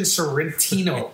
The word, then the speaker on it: Sorrentino: Jersey Shore's Mike the Situation Sorrentino.